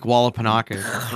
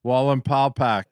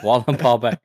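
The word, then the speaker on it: Wallenpaupack: You're saying it like Lake Wallapanaka or whatever. – Yeah. – Like, Wallen Paw Pack. – Wallen Paw Pack. Walmart Snack Pack.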